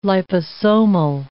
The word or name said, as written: liposomal